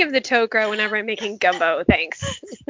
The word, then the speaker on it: Tok'ra: I think I say Tok'ra. Like okra, but Tok'ra, because I'm a lazy American for the most part.